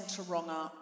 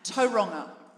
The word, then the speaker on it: Tauranga: I don't want to go on about it, but my birth mother is the head of the Tauranga chapter, and I know I'm saying Tauranga wrongly. How do I say it? – Tauranga. – Every time. Tauranga. Is that correct? – Pretty close, yeah. – Tauranga.